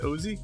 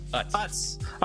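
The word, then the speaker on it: Utz: Was it Uzi? Uzi? Uzi? How do you say that, Brian? Uzi? – Uts. – Uts. Uts.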